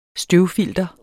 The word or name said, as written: støvfilter